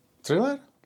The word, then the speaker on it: thriller: Michael Jackson to vyslovuje. Thriller. Tak to vyslovuje Michael Jackson. No. Musíš to vyslovat jako Michael Jackson.